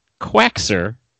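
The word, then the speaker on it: Quackser: That would be like the sound the duck makes. Quack, followed by S-E-R. Quaxer Fortune.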